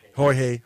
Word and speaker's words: Jorge: El Raton featuring Jorge, who I was pronouncing it. – Jorge. – I was pronouncing it correctly earlier. Then I watched someone else say it, and I got it wrong.